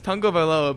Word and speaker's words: Tagovailoa: First of all, it's Tagovailoa, but he's like, I think Tua Tagovailoa is going to be a Hall of Famer.